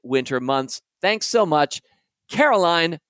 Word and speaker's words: Caroline: Pronounced the way Neil Diamond says it. Pretty sure that's sweet Caroline.